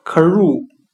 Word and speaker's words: crew: 我们可以用谐音法来记忆这个单词 crew，它的发音很像汉语的可入，可以的可，加入的入。